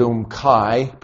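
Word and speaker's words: Umm Qais: This is Umkai pronounced, and it's Gadara, of the Gadarenes.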